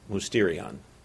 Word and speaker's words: mysterion: the Greek word mysterion. That's how it's pronounced, mysterion.